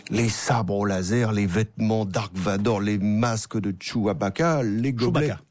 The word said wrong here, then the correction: Chewbacca: Chewbacca, pardon, j'en rallonge d'une syllabe.